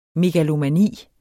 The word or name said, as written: megalomani